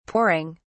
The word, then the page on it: pouring: British English